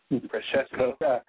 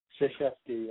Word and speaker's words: Krzyzewski: How do you pronounce Coach K's name? Krzyzewski. – Krzyzewski, yes. – Krzyzewski, yeah, I think it's like that. So those are your Olympic team.